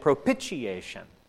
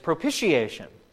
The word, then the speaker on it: propitiation: And in one of the sermons I listened to this week, the preacher was calling it propitiation. Pitch, propitiation. That's not how you say the word. It's propitiation. It rhymes with initiation.